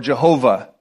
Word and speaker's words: Jehovah: And they translated, because they're Hebrew letters translated into English, it's either YHWH or JHVH. You will have heard them pronounced either as Yahweh or Jehovah.